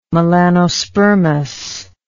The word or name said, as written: melanospermous